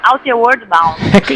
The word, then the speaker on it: Outward Bound: Esa era una cuestión que también que queríamos preguntarte, lo que nos acabas de comentar, corrígeme, porque mi pronunciación tampoco es que sea muy, muy buena, Outward Bound, ¿no? – Sí. – Se trata. – No, que lo diga ella, que lo dice mejor. ¿cómo se dice? – Sí, es una escuela que se llama Outward Bound. – Igualito, ven.